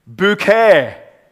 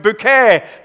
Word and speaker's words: Bucket: There's this lady called Hyacinth Bouquet, spelled Bucket, B-U-C-K-E-T, but she wanted it pronounced Bouquet because she was posh.